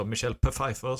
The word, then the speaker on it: Michelle Pfeiffer: Men det gör ingenting, för man följer Selina Kyle, spelad mästerligt av Michelle Pfeiffer, eller Michelle Pfeiffer, som jag tycker man ska uttala det.